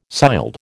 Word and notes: sild: American English

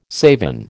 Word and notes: savin: American English

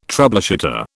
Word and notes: troubleshooter: British English

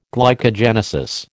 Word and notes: glycogenesis: American English

